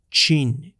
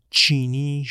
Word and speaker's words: Qin: این شیه هوانگ تی همون کسیه که در این قسمت او رو با عنوان چین شی هوانگ می‌شناسیم.